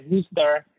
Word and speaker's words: Worcester: I mean, they write it Worcester, but they pronounce it as Worcester.